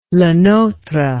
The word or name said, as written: Le Notre